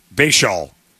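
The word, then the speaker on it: Bhayshul: I don't know how to say it, for Bashaun Tuten in Jacksonville? – Bashaun.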